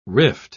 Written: rift

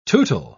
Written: tootle

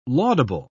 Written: laudable

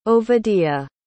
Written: Ovadia

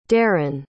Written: daryn

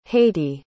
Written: haiti

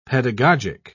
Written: pedagogic

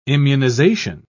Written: immunization

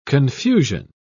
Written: confusion

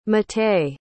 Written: matei

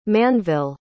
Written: Manville

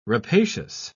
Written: rapacious

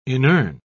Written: inurn

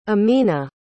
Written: amina

